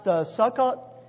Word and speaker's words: Sukkot: Sometimes you'll see it listed as, I believe it's pronounced Sukkot. S-U-K-K-O-T is a Hebrew word, which literally means Feast of Booths.